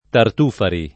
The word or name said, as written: Tartufari